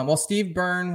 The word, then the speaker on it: Steve Byrne: So far, the biggest names Steve Byrne was a pretty big name.